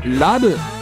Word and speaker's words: LUV: LUV と書いてラブと言います。